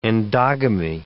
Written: endogamy

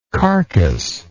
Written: carcas

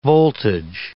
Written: voltage

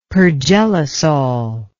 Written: pergelisol

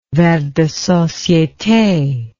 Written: vers de societe

